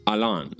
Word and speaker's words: Alain: Mi nombre, porque si bien es francés, eh, algunos me conocen como Alan, otros como Alain, otros como Alain, y para los que saben francés me dicen Alain.